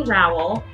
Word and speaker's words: Rowell: Did I say your last name right? I don't know if it was Rowell or Roll. – No, it's Amy Rowell.